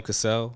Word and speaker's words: Cassell: I feel like Sam Castle is how you say it. – Sam Cassell. Oh my God, Sam Castle.